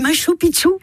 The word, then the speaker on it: Machu Picchu: Le paysage de Mongolie ou bien le Machu Picchu au Pérou. – Machu Picchu. On dit Machu Picchu.